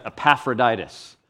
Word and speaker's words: Epaphroditus: And then in verses 25 through 30, we'll learn from this guy named, let's say this word together, Epaphroditus. Epaphroditus.